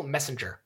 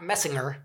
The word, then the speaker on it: Messinger: Okay, let's talk about Dennis Franz's character, Nathaniel Messinger. – Okay. – Not Messinger. It's a soft G.